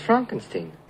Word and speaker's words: Frankenstein: No, it's pronounced Frankenstein. – If you're not sure, a good rule of thumb is this. With surnames or words that are from German, E-I is pronounced I.